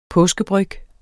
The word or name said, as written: påskebryg